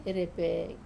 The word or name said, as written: repe